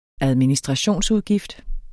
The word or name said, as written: ADMINISTRATIONSUDGIFTER